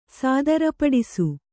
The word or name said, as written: ಸಾದರಪಡಿಸು